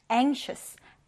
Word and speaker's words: anxious: 첫 음절의 강세를 두고요. 여기 발음 기호 잘 보면 여기 이렇게 꼬리가 긴 N처럼 생긴 발음 기호 있잖아요. 이거는 N 이렇게 발음이 되고 그리고 여기 S처럼 생긴 그런데 기다란 S처럼 생긴 이 발음 기호는 슈, 슈 이렇게 발음이 됩니다. 그래서 anxious. anxious 이렇게 보시면 돼요.